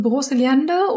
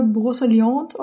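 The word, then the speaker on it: Brocéliande: Äh, auf Deutsch ausgesprochen wäre es Broseliande oder Broseliante auf Französisch.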